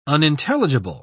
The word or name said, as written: unintelligible